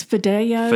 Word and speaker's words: fideo: Pronounce the name of the pasta. – It's Fideo.